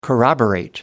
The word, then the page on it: corroborate: American English